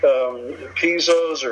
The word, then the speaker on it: piezo: I typically don't like piezos or piezos. I actually had to look that up on how to pronounce that. Either is acceptable, either piezo or piezo.